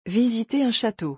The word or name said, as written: Visiter un chateau